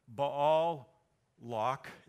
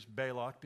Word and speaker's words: Balak: His name, Balak, you could say Baal. You remember him, right? The god. Baal Lock, you could call him or say his name that way. I'm just going to use Balak because that's the way I learned when I was a little kid in Sunday school.